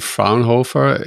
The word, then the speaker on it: Fraunhofer: You're working with CertX and Fraunhofer AIIS. That's the correct way to pronounce it.